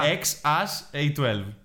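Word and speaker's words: X Æ A-12: AI νομίζω προφέρεται. – AE. Όχι. Είπε ότι προφέρεται AS. – Α, ναι, AS, σωστά. – XAS A12.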